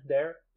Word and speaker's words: Der: Am I pronouncing that right? D-E-R in German? Der? – Der.